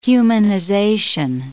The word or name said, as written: humanization